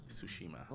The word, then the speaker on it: Tsushima: Yeah, I believe it's Ghost of Tsushima, but so there's no T. That T is silent. Little T is silent. – Yes. Yes. Ah. No T. Tsushima.